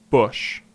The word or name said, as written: Bush